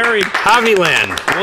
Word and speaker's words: Hovland: Hovland. – Pronounced it correctly.